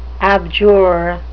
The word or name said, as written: abjure